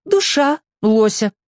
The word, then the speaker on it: Душа лося: «А ты назови душа лося», — посоветовала я. «Ну, с ударением на «я», конечно». – Душа лося Симбирцеву понравилась больше.